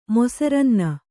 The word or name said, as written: ಮೊಸರನ್ನ